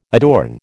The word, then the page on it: adorn: American English